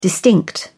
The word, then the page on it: distinct: British English